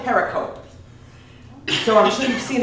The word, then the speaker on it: pericope: I'm writing on the That pericope doesn't exist in any of the Dead Sea manuscripts. – What's the word you're using? – Pericope.